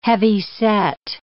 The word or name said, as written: heavyset